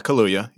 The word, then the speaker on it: Kaluuya: It stars Daniel Kaluuya. How do you say this? – Yeah, Kaluuya, yeah.